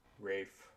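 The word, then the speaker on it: Ralph: Rafe, like rave, but Rafe. – Well, it's like rave and reef. – Well, I was gonna say it's like strafe without the st.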